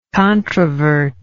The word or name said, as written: controvertibly